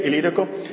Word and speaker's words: Ilírico: Bueno, ¿lo dije bien, Ilírico? Muy bien. Él había predicado desde Israel hasta la provincia más lejana, hasta la provincia romana más lejana conocida en ese tiempo, que era Ilírico, lo que ahora es Croacia.